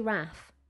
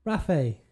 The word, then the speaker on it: raphe: I say RAF. – RAFE. – RAFE. – Okay. – Scrotal Raphe sounds like, you know, Raphael Nadal or something.